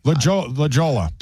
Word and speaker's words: La Jolla: Now, I know for a fact that you have pronounced La Jolla properly. – La Jolla. – Yeah. It's the same thing. La Jolla, J-O-L-L-A.